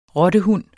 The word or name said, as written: rottehund